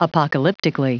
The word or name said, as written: apocalyptically